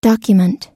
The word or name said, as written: document